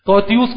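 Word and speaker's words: Titus: ٹائٹس، توتیوس کر کے بھی اس کا اردو میں تلفظ بنتا ہے،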